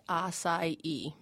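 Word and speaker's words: açaí: And am I saying that correctly? – You are saying it correctly. It's pronounced acai. Most people pronounce it acai.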